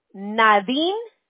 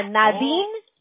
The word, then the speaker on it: Nadine: Te lo voy a mandar por texto, pero mira, mi nombre es Nadine Medina. Nadine Medina. Se escribe N A D I N E.